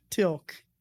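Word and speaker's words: Teal'c: Tilk. – Yeah. I was going to say Tilk or Tilk. I'm bad with names, you know. – Tilk. – Tilk. – T-E-A-L apostrophe C. Tilk.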